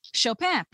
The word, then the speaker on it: Chopin: In the Harper American Literature, it says, Chopin, pronounced in the French way, as in the name of the pianist, Frédéric Chopin.